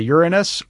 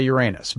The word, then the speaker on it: Uranus: Well, both are acceptable. You can say Uranus or you can say Uranus. Both are acceptable in the astronomical community.